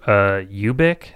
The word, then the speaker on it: Ubik: Apparently Linklater was going to do Ubik.